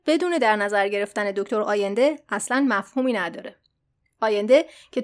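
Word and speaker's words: Allende: البته توی پرانتز یه چیز رو بگم، ما در ایران اسم ایشون رو به صورت سالواتور آلنده تلفظ میکنیم که البته اشتباهه. تلفظ درست هست سالواتور آینده.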